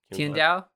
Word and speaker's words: Qingdao: You don't pronounce Q's. – Okay, how do you pronounce it then? – So, yeah. – Qingdao. – Qingdao? Okay. – Probably. Yeah. I don't know the tone.